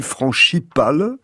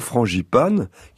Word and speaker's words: frangipane: bon, alors d'où vient le mot frangipane hein Alors d'abord, il a été prononcé frangipane, puis frangipale, mais c'est bien sûr frangipane qu'il faut dire,